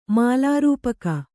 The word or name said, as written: ಮಾಲಾರೂಪಕ